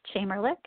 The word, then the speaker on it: Chamerlik: And my name is spelled Erin, E-R-I-N, Chamerlik, C-H-A-M-E-R. L-I-K, and mention this podcast because I don't let just anybody in.